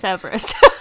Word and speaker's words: Cerberus: I think we get minor flashbacks, but not a lot, because that's more Dirge's Cerebus, isn't it? Or Cerberus, I'd never say that right. – Cerberus.